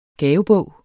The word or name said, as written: gavebog